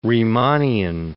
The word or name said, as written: Riemannian